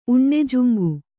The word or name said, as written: ಉಣ್ಣೆಜುಂಗು